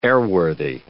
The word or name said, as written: airworthy